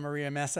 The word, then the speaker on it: Maria Mesa: I'm super excited because my very good friend Brad Koss and my very good friend Maria Mesa and I are having a great conversation about all things related to digital wellness.